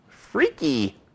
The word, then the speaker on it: friki: Anyway, it originated in Spain in 2006, as I said, as Dia del Orgullo Freaky. I like the freaky part, even though it's spelled F-R-I-K-I.